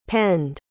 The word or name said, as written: penned